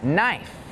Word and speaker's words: knife: Some examples with a silent K are the words knee, night, and knife.